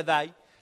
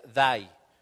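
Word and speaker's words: dai (δεῖ): La palabra debes, vamos a ver la siguiente lámina es la palabra griega dai, se pronuncia dai, la transliteración, o sea, ¿qué es la transliteración? Es la transferencia del griego al, español, se escribe dei, pero se pronuncia dai.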